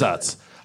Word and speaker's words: Utz: Did I say it right? Is it Utz? It is Utz.